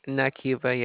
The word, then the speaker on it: Nakeiva: Is Nakiva. – Nakiva? – Nakiva, yes. – How do you spell it? – N-A-K-E-I-V-A.